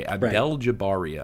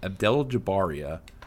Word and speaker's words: Abdeljabaria: let me pronounce this. Aljabara, yeah? – Abdeljabaria. – I should know your last name. Abdeljabaria. Okay, Abdeljabaria. Right. So if you put that on the map, Abdeljabaria, then you would be like, what the hell is that?